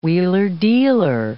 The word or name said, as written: wheeler-dealer